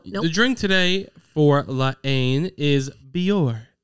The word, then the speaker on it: La Haine: Like the H is silent. – La Haine.